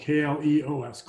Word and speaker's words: kleos: He wants, and the Greeks called it kleos. That's spelled Kleos.